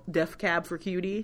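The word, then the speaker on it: Death Cab for Cutie: It's because Def Cab for Cutie. – Really good. You said it right.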